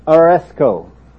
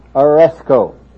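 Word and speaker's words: aresko (ἀρέσκω): This is, for all you who want to learn, this is how you say the word, aresko. Everybody say that, aresko.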